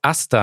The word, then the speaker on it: Astha: Asta. – Yeah. It's Asta. So I tell people it's like pasta, but just remove the P.